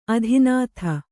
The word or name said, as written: ಆದಿನಾಥ